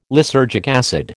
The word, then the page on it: lysergic acid: American English